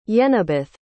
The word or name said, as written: yenibeth